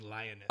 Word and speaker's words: Liones: L-I-O-N. E-S. – Okay, yeah, that's... – She knocked out one S. – Yeah. Yeah, I like how you're pronouncing it like it's not a female lion. You're like, Leoness. No, don't make it all French. It's Lioness.